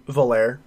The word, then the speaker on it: Volare: The Quest Valaire, V-O-L, Valaire, Valar? V-O-L-A-R-E? Valaire? How do you say it? – Valaire.